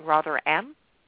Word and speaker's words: Rotherham: And as a matter of fact, myself of EDL Radio, we're going to be speaking extensively about what happened in Rotherham. And I probably said that wrong. Rotherham, but Rotherham.